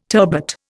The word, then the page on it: turbot: Indian